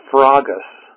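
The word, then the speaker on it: sphragos (σφραγίς): It's pronounced like this, sphragos.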